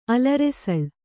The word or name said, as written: ಅಲರೆಸಳ್